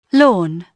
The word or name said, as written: lawn